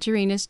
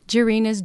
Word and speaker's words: Jirina: Well, the name of my project is called Jirina's Journey, and it's a series of collectible treasure books.